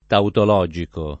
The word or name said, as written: tautologico